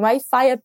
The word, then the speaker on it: Wi-Fi: Yeah, sim, Wi-Fi, apesar de vir do inglês e ser escrito da mesma forma, é pronunciado à la française, Wi-Fi.